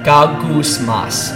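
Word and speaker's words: gagousmas (γογγυσμός): It's not the first word I pronounce, it's gagousmas.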